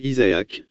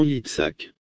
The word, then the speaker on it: Yitzhak: Yitzhak signifie « rire » et vous le prononcez Isaac, mais c'est vraiment Yitzhak.